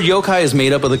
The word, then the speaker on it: yokai: You pronounced that wrong. – That's it wrong. It's pronounced yokai. Yaki. It's pronounced yaki.